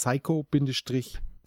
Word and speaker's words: Psycho-Pass: Und zwar habe ich das S jetzt wirklich das TH, was klingen würde wie ein TH, wie ein S ausgesprochen, weil es tatsächlich Doppel-S ist. Es ist Psycho-Pass, Pass. wie das Pass, Passport oder Pass.